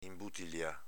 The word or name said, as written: inbutilià